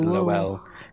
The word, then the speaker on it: Lowell: Definitely Lowell. Because they pronounce Noel Noel, so I would imagine it's Lowell. – Yeah, but Noel's spelled N-O-E-L, isn't it? – Unless it's spelled with a W-E-L-L-E.